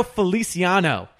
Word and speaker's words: Feliciano: Don't you dare question my mispronunciation of black belt when you say Feliciora. Where is there an H? – Josh, it's a silent H. – Ricardo Feliciano.